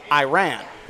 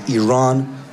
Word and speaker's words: Iran: Our country is named Iran, not Iran.